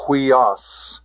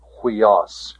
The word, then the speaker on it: huios: So it is pronounced hui. The rough breathing mark being the H sound, huios, huios.